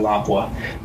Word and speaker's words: Lapua: Lapua just, I think they pronounce it Lapua, but us idiots in the U.S. call it Lapua.